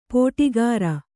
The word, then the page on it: ಪೋಟಿಗಾರ: pōṭigāra